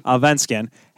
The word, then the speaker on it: Allsvenskan: Allsvenskan has not scored a goal yet, but that doesn't matter.